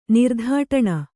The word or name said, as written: ನಿರ್ಧಾಟಣ